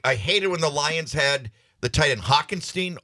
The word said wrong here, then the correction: Hockenson: How do you pronounce his last name? – Hockenson.